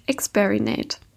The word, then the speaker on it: Experinate: darf heute mit Nadja Herz sprechen, Beraterin im Bereich Organisationsentwicklung und Gründerin des Pop-Up-Stores Experinate. Habe ich das richtig ausgesprochen? Ja, genau.